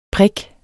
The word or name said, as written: prik